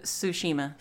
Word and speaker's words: Tsushima: There's a T and an S there, right next to each other. Don't know how to do it. – The Ghost of Tsushima. – Tsushima. – The T is silent. – Ah, silent T.